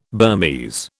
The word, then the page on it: Burmese: British English